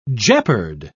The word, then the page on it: jeopard: British English